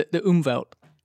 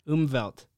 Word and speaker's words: Umwelt: Yeah, it's the Umwelt. – Umwelt.